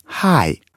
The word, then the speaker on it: hi: Послушай, hi, звук не такой, как в русском слове «хлеб». Произносишь его нежно, с придыханием, как будто ты бежал, запыхался и выдохнул. Hi.